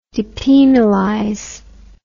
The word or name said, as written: depenalize